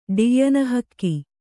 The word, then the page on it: ಡಿಯ್ಯನಹಕ್ಕಿ: ḍiyyana hakki